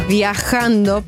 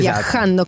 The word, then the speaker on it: viajando: In questo sabato, buongiorno, tutto bene a 105, ma non sapete che la pronuncia corretta è questa. – Viajando por el mundo, esatto. Viajando con la J, la sì, sì. Bisogna avere un po' di catarro praticamente.